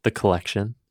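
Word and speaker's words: Kollection: And what that was when I, The Collection, spelled with a K. – Two Ks? One K? – One K. – Okay. – K-O-L-L, action. And so that was the first time I... – Yeah, K-O-L-L, action. – That's how it's pronounced.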